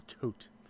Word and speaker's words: Thoth: Khemenu, with the temple of Thoth or Tot, as it's supposed to be pronounced.